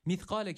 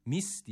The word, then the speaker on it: مِثْقَالَ: Birincisi peltek ve sakin olan F harfi. Yani mith. Bu mis diye okunuyor. Bu da yanlış olur. Burada keskin olan sin harfi yok. Burada peltek olan F harfi var.